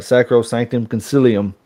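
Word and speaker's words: Sacrosanctum Concilium: A quick line, I should say, from, and hopefully I pronounced this right, Sacro Sanctum Concilium. – Very good. That's good. That's good pronunciation, bro.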